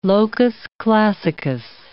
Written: locus classicus

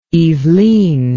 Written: Yvelines

